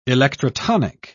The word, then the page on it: electrotonic: British English